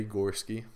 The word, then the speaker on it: Gorskie: It's under J Gorskie, is my first initial and my last name.